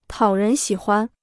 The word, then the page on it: 讨人喜欢: tǎo rén xǐ huan